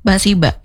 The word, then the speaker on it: Basiba: Oke, okay, nah Bundo, ini tadi kita sudah sedikit mengupas ya baju kurung Basiba. Itu pengucapannya baju kurung Basiba. – Kalau bahasa Minangnya.